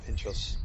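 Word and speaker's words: pintxos: And on my first night sort of in Bilbao, I went out, thought I'll go and get some pinchos, is that how I pronounce it? – Yeah, pinchos.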